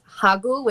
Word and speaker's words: hagu: It's hagu, which is H-A-G-U. So, like, ragu, but hagu, that's one.